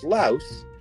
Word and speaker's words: Laos: Now, the only reason I know it's actually pronounced Laos is because I heard the guy on the TV pronounce it as Laos.